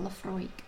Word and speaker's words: Laphroaig: Laphroaig is spelt L-A-P-H-R-O-A-I-G. But you say it Laphroaig. And if you're from Oban, you say it like Laphroaig. – With rolling your R's.